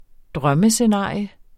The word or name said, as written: drømmescenarie